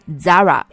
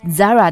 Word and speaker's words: Zara: 今天的话题是非常 interesting 的，因为前几天在和朋友逛街的时候，朋友说他想去那家西班牙的店买衣服，我就问他是哪家，他说 Zara。哎呀，和我做了这么长时间的朋友，仍然管这家店叫做 Zara 吗？它在西班牙语里的发音才不是 Zara 呢。 – Eh, cierto, en español deberíamos decir Zara.